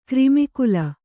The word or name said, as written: ಕ್ರಿಮಿಕುಲ